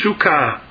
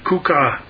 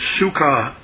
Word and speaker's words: sukkah: Wherever you see the word booths in the Old Testament, the word is sukkah, okay, it's C-U-K-K-A-H, Strong's 5521, Strong's 5521, it looks like kukah, but it's sukkah pronounced, and the Hebrew language experts, they tell us that this word sukkah can mean various types.